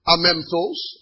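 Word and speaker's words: Amemptos (ἄμεμπτος): Amemptos, I'll spell it for you. A-M-E-M-P-T-O-S. A-M-E-M-P-T-O-S.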